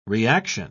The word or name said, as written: reaction